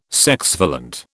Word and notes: sexvalent: British English

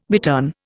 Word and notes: bittern: Indian

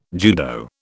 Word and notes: judo: British English

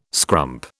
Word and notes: scrump: British English

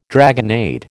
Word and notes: dragonnade: American English

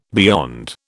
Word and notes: beyond: British English

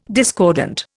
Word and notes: discordant: Indian

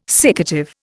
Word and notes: siccative: Indian

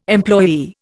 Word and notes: employee: Indian

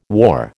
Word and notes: war: American English

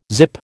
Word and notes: zip: American English

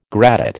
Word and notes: gradate: American English

